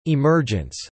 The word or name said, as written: emergence